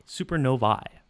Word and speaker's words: supernovae: So as a Latin minor, I can tell you the correct pronunciation is I supernovae. It's classical Latin.